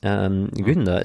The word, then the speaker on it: Gunda: Günther ist ein Film, ich habe das jetzt mal betont norwegisch ausgesprochen, Gunda wird er geschrieben.